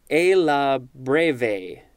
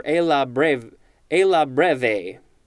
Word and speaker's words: alla breve: The etymology says this is Italian, and it literally means according to the breve, B-R-E-V-E. And it does look like it can also be pronounced a la breve, or a la breve, if you want to say it, you know, properly.